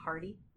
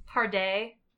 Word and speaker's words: Pardee: It's pronounced Parday. Like crepe.